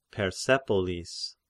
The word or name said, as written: Persepolis